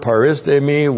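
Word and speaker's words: paristemi (παρίστημι): P-A-R-I-S-T, long E-M-I, paristemi.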